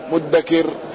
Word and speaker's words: مدكر: قوله يقول مدكر أدالا يعني بالمهملة، وأصله مفتكر أو مفتكر، فأبدلت التاء دالا مهملة، ثم أدغمت المعجمة في المهملة، فصار النطق بدال مهملة مدكر.